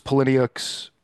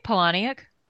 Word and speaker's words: Palahniuk: This was Palahniuk's... How do you say that name? – I say Palahniuk.